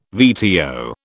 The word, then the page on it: VTO: British English